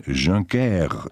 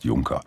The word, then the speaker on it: Juncker: Das ist nämlich ein deutscher Nachname. Und man sagt zwar französisch Jean-Claude, aber der Nachname ist Juncker.